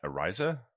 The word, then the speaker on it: Araiza: Next one I think we should touch on is Matt Araiza.